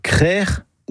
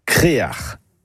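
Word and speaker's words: krec'h: Le mot breton, c'est créar. Et ce mot, on le retrouve dans des hameaux ou des quartiers, même pour un phare. Et la bonne production est crère ou créar. Créar, c'est une prononciation plus du Nord Finistère, mais on la trouve parfois ailleurs.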